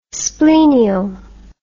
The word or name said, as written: splenial